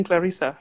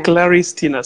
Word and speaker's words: Claris Tina: it's okay it's okay to learn i'm claris tina claris tina so it's not clarissa all right that's great.